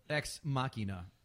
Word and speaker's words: Ex Machina: How did you pronounce it? – Ex Machina. – That's Ex Machina? – Yeah. Like Deus Ex Machina?